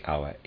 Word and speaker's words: Aue: I'm pronouncing Aue, A-U-E.